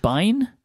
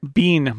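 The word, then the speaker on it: Biehn: How do you say his surname? Is it Bine? – Bean. Michael Bean.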